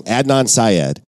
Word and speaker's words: Adnan Syed: The first season was about a 17-year-old young man named Adnan Syed. – I always say that wrong. Adnan Syed.